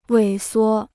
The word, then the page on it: 萎缩: wěi suō